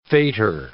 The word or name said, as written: faitour